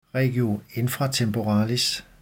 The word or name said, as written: regio infratemporalis